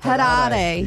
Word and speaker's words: Harare: Which landlocked African country has Harare? I don't know if I'm saying that right. – No. – It sounds like you are. – Harare.